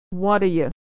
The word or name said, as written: whaddaya